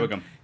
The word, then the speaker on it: Whigham: And also, Shea Whigham. Is that how you say it? Yeah, Wickham.